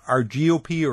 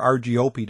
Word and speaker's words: Argiope: This one is from a group we call Argiope or Argiope, depending how you want to pronounce it.